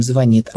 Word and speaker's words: звонит: я, конечно, правильно произношу слова с ударением, звонит. Домой в деревню приезжаю, говорю, звонит знает, кто скажет, в Москве там зазнался.